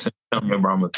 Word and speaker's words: San Antonio Brahmas: You're in the XFL with the San Antonio Brahmas. How you say it? Is that how you say it? – Yes, San Antonio Brahmas. Yes, sir.